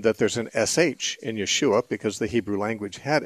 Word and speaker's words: Yeshua: Now, Joshua is not really a Hebrew pronunciation. It's closer to the Hebrew spelling, but it's not the right pronunciation because the Hebrew language doesn't have a J sound. So there's no Joshua. It'd be more like a Y, so it'd be Yahshua or Yeshua.